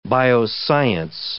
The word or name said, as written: bioscience